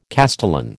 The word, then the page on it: castellan: American English